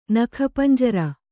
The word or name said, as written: ನಖಪಂಜರ